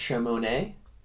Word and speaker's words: Shamone: I really would have insisted that it's pronounced Shamone. – Okay, because that silent E, the unused E. – Yeah. It's an implied E.